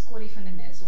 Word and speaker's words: Corrie Van Den Nes: Who is Corrie Van Den Nes? Well, as you pronounce my name in a Dutch way, with a Dutch accent, means, you know, I'm half Dutch.